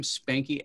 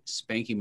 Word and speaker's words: Spanky: And, but if you follow me on social, you can use my nickname, Spanky, S-P-A-N-K-Y, Moskowitz. Maybe I shouldn't spell that. M-O-S-K-O-W-I-T-Z, Spanky Moskowitz on all social channels, Facebook, Instagram, TikTok, Twitter, you name it, you'll find me there.